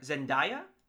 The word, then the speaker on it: Zendaya: But, am I saying this right? Zendaya as MJ? – Yeah.